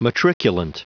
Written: matriculant

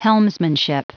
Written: helmsmanship

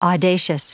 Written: audacious

